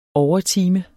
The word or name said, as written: overtime